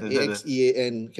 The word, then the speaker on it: Axean: It's actually Axean. – Axean, is it? Is that? – Yeah, Axean can be pronounced as Axean. – Ah, I see. I thought it was AXE, then AN, so XN. You know, like some kind of new millennial speak, I don't know. Who X-E-A-N.